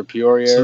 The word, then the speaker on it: Peoria: Peoria, Arizona.